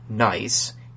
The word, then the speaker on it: Nice: And for those of you who know about the NICE model, which is spelled nice, even though it's Nice, because it's French, to stave off anyone emailing me a question about it, the Nice model also has Jupiter forming roughly where it is today.